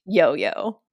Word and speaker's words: LLO LLO: So for our listeners out there, it's spelled LLO LLO, pronounced Yo-Yo.